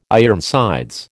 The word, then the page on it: Ironsides: American English